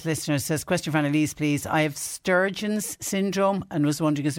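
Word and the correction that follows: Sjögren's: It's the, I'm probably pronouncing it wrong, it's the one with the dry mouth and dry eyes. Oh, yes, yes. Um, Sjogren's.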